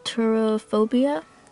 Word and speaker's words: turophobia: It's pronounced turophobia.